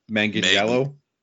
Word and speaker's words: Manganiello: There's I will not pronounce his name correctly at all. Joe Manganiello. – Like that, yes.